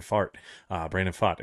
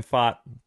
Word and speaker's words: Pfaadt: That was the joke at the beginning of the season, Wes, is we didn't know exactly how to say his name, so I was like, it just kind of sounds like a fart in it. That's how it's spelled, P-F-A-A-D-T. It sounds like he farted. So Brandon Fott, he's going to be the guy who's got to set the tone, right?